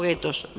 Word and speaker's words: gueto: No hubo guetos, nunca hubo guetos en España. Imposible. De todas maneras, la palabra gueto, que se pronuncia gueto porque es una palabra italiana,